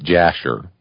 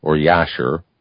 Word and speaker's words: Jasher: And he brought up the book of, some pronounce it Jasher, the book of Jasher or Yasher.